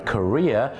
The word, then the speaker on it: career: C-A-R-E-E-R, Korea, ออกเสียงว่า Korea คล้ายๆกับประเทศเกาหลีเนี่ยนะครับอาชีพเนี่ยนะแต่บ้านเราเนี่ยนะครับไปออกเสียงว่า Carrier เนี่ยนะครับ